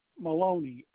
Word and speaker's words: Maloney: No, Maloney. M-A-L-O-N-E-Y. First name, Brian. – Is that B-R-I or B-R-Y? – B-R-I.